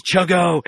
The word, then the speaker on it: CHGO: We have from Swirsky Sports, Alex Pat, great friend, and from, I'll say it right, CHGO, otherwise known as Chuggo, it's Cody Del Mendo from the – Cody hates us so much. – Cahugo.